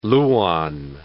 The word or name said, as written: lauan